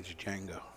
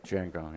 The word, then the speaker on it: Django: The D is silent in Django.